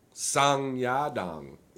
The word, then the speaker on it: Song Yadong: Song Yadong.